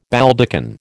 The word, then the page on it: Baldachin: American English